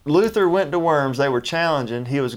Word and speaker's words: Worms: The Germans pronounce it very. It's spelt worms, which always amused me, but it's pronounced worms.